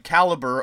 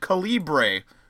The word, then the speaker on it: Calibre: Like, Caliber of a Gun. Except it's spelled... Mm-hmm. It's a British movie. So when I first saw it, I saw it and I thought it was called Calibre. Because it's spelled R-E at the end. Then I watched it and I was like, that's not what the movie was called.